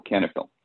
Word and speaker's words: Cannaphyll: It's spelled CANNAPHYLL, Canafil.